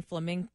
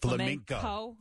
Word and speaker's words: flamenco: Now, this guy, he lost because of that mispronunciation and saying flamingo instead of flamenco.